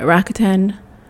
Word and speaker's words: Rakuten: And number six is Rakuten. I think that's how you say it. Rakuten.